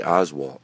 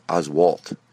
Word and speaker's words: Oswalt: I, I know I say Oswald. It's Oswalt. Jeez, get it right.